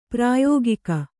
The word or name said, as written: ಪ್ರಾಯೋಗಿಕ